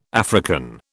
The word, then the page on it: African: British English